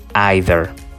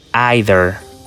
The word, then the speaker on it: either: O su alternativa, either. Entonces, primero la pronunciación. To, to y either, either o either, either.